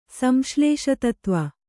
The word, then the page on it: ಸಂಶ್ಲೇಷತತ್ವ: kannada